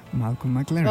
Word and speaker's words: Malcolm McLaren: And before that, Malcolm McLaren.